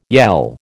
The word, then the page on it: yell: American English